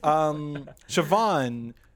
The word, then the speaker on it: Siobhan: It's Siobhan. – Oh, well, it's okay. Yeah. So it's pronounced much different than how it's written.